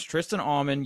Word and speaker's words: Tristan Auman: Your name is Tristan Auman.